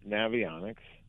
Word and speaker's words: Navionics: Okay, so you have Navionics. Could I say it right? – Yes, you did.